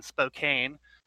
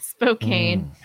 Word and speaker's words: Spokane: It is spelled like that. Like the first few months that I moved here, I was calling it Spokane. People would be so disgusted. I'm like, but look at the way it's spelled.